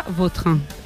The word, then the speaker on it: Vautrin: La original francesa, Vautrin.